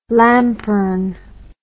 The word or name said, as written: lampern